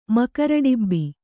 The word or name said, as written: ಮಕರಡಿಬ್ಬಿ